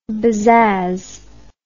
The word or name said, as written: bezazz